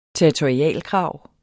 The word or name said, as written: territorialkrav